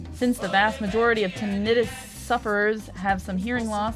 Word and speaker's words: tinnitus: It's pronounced tinnitus? I've been saying it wrong this whole time.